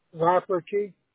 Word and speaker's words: Rafferty: Rafferty. – How do you spell that? – R-A-F-F-E-R-T-Y.